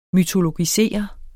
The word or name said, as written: mytologisere